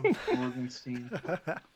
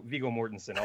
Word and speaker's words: Mortensen: Mortensen. – Oh, nice. Okay. Viggo Mortensen. I'll say his name the correct way.